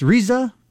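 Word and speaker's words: RZA: So Good Humor has decided to team up with, is it pronounced Riza, of Wu-Tang Clan. – Oh, yeah. Yes, Wu-Tang.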